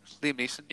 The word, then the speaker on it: Liam Neeson: Am I saying his name right? Liam Neeson? Yeah. – Yeah. – Liam Neeson.